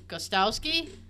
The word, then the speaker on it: Gostkowski: Gostkowski? – That sounds good.